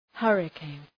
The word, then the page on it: hurricane: ‘hɜ:rı,keın; ‘hʌrı,keın